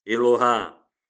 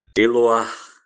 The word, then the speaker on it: Eloah: Para que essa dinastia viva eternamente na tua presença, porquanto o que tu, Yahvé, Elohim, Elohá, Eloah, a pronúncia mais correta, Hashem, o nome, eu sou, porquanto, ó, que tu, Yahvé, abençoas, abençoado permanece para sempre, ó Senhor.